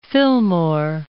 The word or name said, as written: Fillmore